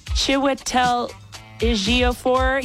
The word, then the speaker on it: Chiwetel Ejiofor: Chiwetel Ejiofor. – You said that perfectly. Good job.